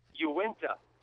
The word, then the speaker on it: Uinta: I got to say, you put the emphasis in the wrong syllable there, Ray. It's Uinta.